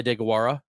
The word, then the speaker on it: Deguara: They have a respectable blocking tight end, Josiah DeGuara, but Tucker Kraft was Can you say that name again for me, please? – Josiah DeGuara. – That's pretty good pronunciation.